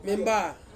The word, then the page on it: memba: Ansus